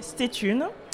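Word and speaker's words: Stay Tunes: Et tu es là surtout pour nous parler du compte et du projet Stétune donc Thune, je le répète, ça s'écrit S-T-A-Y et plus loin T-U-N-E-S.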